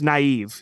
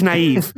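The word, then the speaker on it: Nieve: It's spelled N-I-V-E, but it's pronounced Naive. He's Steve Naive.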